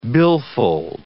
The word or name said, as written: billfold